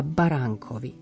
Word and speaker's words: baránkovi: Baránok, tu mi to n zaznieť musí ale baránkovi je len také mierne naznačené N. Dajte veľký pozor, aby ste sa nesnažili prečítať to písmenkovo.